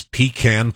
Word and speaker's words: pecan: I know the proper Utah pronunciation is pecan, but just to defend here, when Obama said, yes, we can. – Yes, we can. – He didn't say, we con. He said, we can. Pecan.